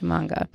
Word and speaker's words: manga: Am I saying that right? Manga or manga? Manga. – I think so, yeah.